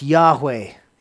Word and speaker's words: Yahweh: It's pronounced Yahweh in Hebrew.